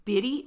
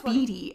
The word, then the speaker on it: bidi: So while searching the perimeter, which is his assignment, Nick finds a beady cigarette. He said beady, and I was like, what the hell are you saying? – what is that? – It's spelled BIDI, cigarette, and a used match.